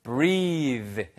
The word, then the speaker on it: breathe: breath가 아니고요. breath까지만 쓰면 숨이란 뜻입니다. breath, breath, 그렇죠? 네, 그런데 breathe 해서 E가 붙는 순간 소리가 바뀌는 거죠.